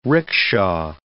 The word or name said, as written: rickshaw